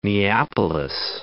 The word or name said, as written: Neapolis